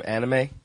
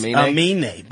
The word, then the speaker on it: Aminé: I think people know who Aminé is. Everybody knows that song. – Everyone knows that song. That's his favorite. That's his. – How you spell his name? Anime? – It's Aminé.